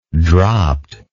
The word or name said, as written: dropt